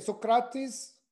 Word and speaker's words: Socrates: Well, so then, like a lot of, you know, so like the people we just named off, Aristotle, Plato, Socrates, or how do you say it? Socrates.